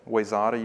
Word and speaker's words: Wayzata: It's like Wayzata, right? Have I said that before? I probably have. Wayzata, you guys pronounce Wayzata, Wayzata, is that right? But it's spelled Wayzata.